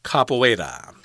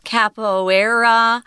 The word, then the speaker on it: capoeira: And this word, I guess, is from Portuguese, and it's pronounced capoeira capoeira. Um, you'll hear Americans often call it capoeira. Um, either way is cool.